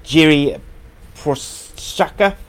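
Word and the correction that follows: Jiří Procházka: Prazka, I think, yeah. – Prazka. I'm glad you're here, James, to let me know.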